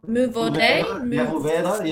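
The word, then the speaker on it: Mourvèdre: You might have to pronounce that one. Mouvaudé. – Mouvaudé, yes. Yeah, very good.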